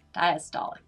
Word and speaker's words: diastolic: What is normal blood pressure, and what's the difference between systolic and diastolic? – That's a really good question. – Okay. – No, you great. You said them great. – Did I say them right? Okay, good.